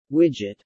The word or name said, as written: widget